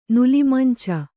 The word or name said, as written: ನುಲಿಮಂಚ